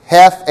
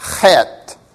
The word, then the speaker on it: chet: Does your Bible have the word heth, H-E-T-H, above that? That's actually the Hebrew letter chet. Chet, which is how you say that. You've got to be careful when you're facing somebody and you say that. But that corresponds to what in English? Well, we don't really have an English letter that precisely corresponds to it. It's kind of like an English H, which is why we usually spell it with an H, heth, but it's really not that soft H sound. It's a ch, ch, het.